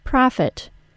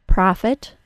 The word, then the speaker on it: profit: The only exception I found to the above pattern is the word profit, which is pronounced with a short O sound instead. Listen closely. Profit, not profit.